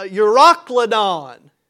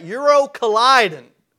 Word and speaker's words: Euroclydon: I used to pronounce that Euro colliding. Like a husband and wife colliding, you know, Euro colliding. Well, that's not how you pronounce it, but they're in a great storm, almost like a, a tornado, if you will.